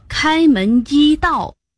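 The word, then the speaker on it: 开门揖盗: 这根本就是开门一道。 – 是开门一道。 – 一不是座椅吗？像这样在胸前，左手抱着右拳头，身子略弯，就是拱手礼啊。 – 是拱手礼。座一，一第一声。 – 原来是座一，所以得读作开门一道。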